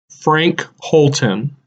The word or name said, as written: Frank Holten